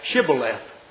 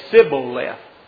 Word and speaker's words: Shibboleth: And they would be required to say Shibboleth. Those from Ephraim couldn't pronounce it. They couldn't pronounce the H. So they would say Sibboleth.